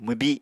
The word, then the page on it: mwbi: Apatani